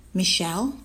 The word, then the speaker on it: Michelle: Michelle, that's spelled M-I-C-H-E-L-L-E.